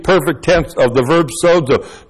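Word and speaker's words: sozo (σῴζω): That's S long O, Z long O, sozo.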